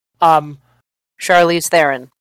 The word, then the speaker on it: Charlize Theron: Trelease Theron. I probably butchered the name. This is one of those names that I keep on reading. I just keep on mispronouncing. – Charlize Theron.